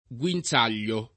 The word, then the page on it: guinzaglio: lingua italiana